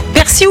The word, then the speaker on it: persil: Alors normalement c'est persil, ok, ouais. D'après le dictionnaire de l'Académie française, mais le nord et le sud du pays prononcent quand même le L, donc t'as le droit quand même aux deux.